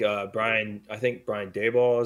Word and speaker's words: Daboll: I think Brian Daboll is how you say his last name. – Yep, yeah, that's it.